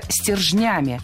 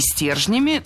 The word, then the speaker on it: стержнями: Еще в этом высказывании мы услышали слово «стержнями». И на всякий случай скажу, это верно. Но и «стержнями» тоже литературная норма. В слове «стержнями», «стержнями» двойное ударение.